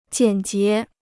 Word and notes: 简洁: jiǎn jié

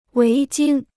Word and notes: 围巾: wéi jīn

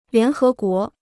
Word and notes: 联合国: lián hé guó

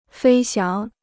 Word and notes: 飞翔: fēi xiáng

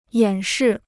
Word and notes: 演示: yǎn shì

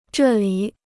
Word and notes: 这里: zhè lǐ

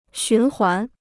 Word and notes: 循环: xún huán

